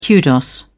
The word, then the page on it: kudos: British English